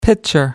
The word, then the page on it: pitcher: American English